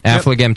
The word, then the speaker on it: Affligem: But then, so what we did was we paired this with Affligem Triple. Mm-hmm. Do you want to correct me on that pronunciation, Nate? Am I doing that right? – That's correct. – Affligem Triple.